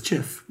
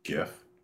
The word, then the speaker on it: GIF: For example, gaff, gif, guff, and guffaw. The word gift is the closest word to GIF, and it has a hard G. To pronounce GIF, just say gift without the T.